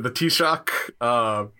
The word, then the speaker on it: Taoiseach: I think here's my attempt at pronunciation. The Taoiseach. – Yes. Yeah. Very good.